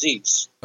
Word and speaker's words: Ziese: Charles Zeese. Z-I-E-S-E. Pronounced Zeese.